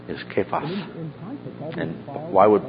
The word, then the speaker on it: Kephas: Now, Cephas is the anglicized pronunciation. The Aramaic is actually Kephas. Kephas. So where you see Cephas in your New Testament, actually the original was Kephas.